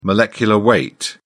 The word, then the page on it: molecular weight: British English